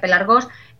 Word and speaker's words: pelargos: Y es curioso porque esta ley se llamaba pelargonia y pelargonia eh, y viene de la palabra griegos pelargos, que se escribe muy raro pero se pronuncia pelargos, que significa cigüeño.